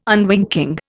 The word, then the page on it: unwinking: Indian